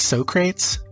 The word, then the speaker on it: Socrates: It's pronounced Socrates.